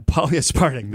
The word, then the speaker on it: polyaspartic: Yeah, polyaspartic. There, that's how you say it.